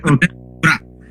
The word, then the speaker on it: Gura: The first one was Gundet Gure. I never know how to pronounce this, or like... – Gura.